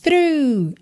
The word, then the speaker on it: through: Pensa E-M-T-Y, tá? Empty. A palavra through, through, ela não é difícil, na verdade, de pronunciar. Eu acho que a escrita dela é que confunde um pouquinho, né? T-H-R-O-U-G-H. Mas olha, o T-H você começa com a linguinha lá na ponta dos dentes da frente, né?